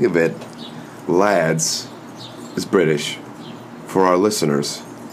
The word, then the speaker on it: LHADS: The cool thing is that Let's Have a Drink Sometime actually kind of spells out lads. – Yeah. – And the H is silent.